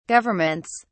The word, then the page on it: governments: British English